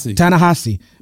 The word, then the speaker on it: Ta-Nehisi: Ta-Nehisi.